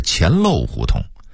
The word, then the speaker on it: 钱粮胡同: 事实上北京胡同里这种以讹传讹的正经不少呢，比如说钱粮胡同，准确的发音应该是钱漏胡同，那个粮字应该读成漏。